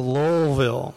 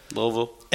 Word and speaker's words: Louisville: It's like you're not supposed to pronounce the O, the U, the I, or the S. It's just like Louisville,